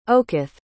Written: okoth

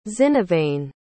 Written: xinavane

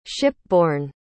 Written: shipborne

